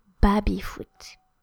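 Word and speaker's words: baby-foot: So it's baby foot, but we pronounce it baby foot. So à la française.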